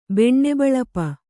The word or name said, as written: ಬೆಣ್ಣೆಬಳಪ